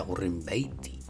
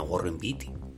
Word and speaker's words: Warren Beatty: Que parece ser, por cierto, que se pronuncia Warren Beatty no Warren Beatty.